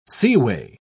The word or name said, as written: seaway